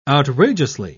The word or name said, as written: outrageously